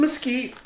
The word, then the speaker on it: Mesquite: There's no K. – Oh, boy. There isn't. You're right, but it's Mesquite. Okay. – How? – I don't know. – It's spelled M-E-S-Q-U-I-T-E.